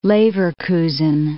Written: Leverkusen

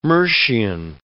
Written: Mercian

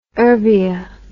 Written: urbia

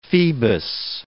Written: Phoebus

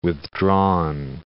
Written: withdrawn

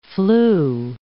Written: flew

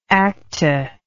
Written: acta

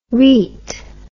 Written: reet

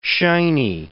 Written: shiny

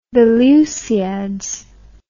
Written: Lusiads, The

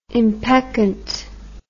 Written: impeccant

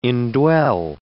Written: indwell

